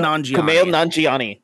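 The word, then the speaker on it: Kumail Nanjiani: Kumail Nanjiani.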